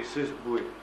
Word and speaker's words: Cesboué: Et Cesboué, comme ça se prononce également, qui ont fait une analyse d'un point de vue protestant, puis d'un point de vue catholique, de la situation du cumulisme.